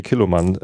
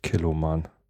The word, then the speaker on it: Kilchoman: Ich sagt man nicht. Kilchoman. Also es schreibt man K-I-L-C-H-O-M-A-N spricht Kelloman.